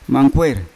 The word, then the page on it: mangkwei: Yawa